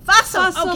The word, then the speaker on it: Fussell: Fussell, okay. Like Russell. – Fussell like Russell.